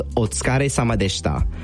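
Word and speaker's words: otsukare sama deshita (お疲れ様でした): The word is pronounced otsukare sama deshita or otsukare sama desu.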